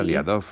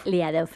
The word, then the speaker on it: Liadov: Era Liadov. – Liadov,